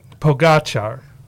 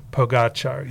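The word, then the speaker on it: Pogačar: Pogacar. – Okay. – Yeah. Tadej Pogacar.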